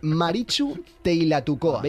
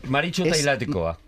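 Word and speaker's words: Maritxu Teilatukoa: tienen una tradición preciosa que es la de Marichu Teilatucoa. Veis que no he puesto acento vasco, ¿no? Gracias. – ¿Has pronunciado TX o un H? – Yo he intentado el TX. – Vale. – Marichu Teilatucoa.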